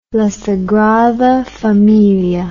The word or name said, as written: La Sagrada Familia